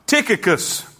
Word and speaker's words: Tychicus: All my state shall Tychicus, like a wood tick, or a tick on a red bone hound. Say amen. Say it with me, Tychicus.